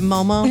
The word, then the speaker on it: Momo: Oh, is it Momo? – No. You said it right.